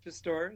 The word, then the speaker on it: Pistor: Pistor or Pistor is fine.